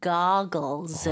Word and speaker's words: goggles: 네, 발음 주의하셔야 돼요. 고글보다는 영어로는 goggles.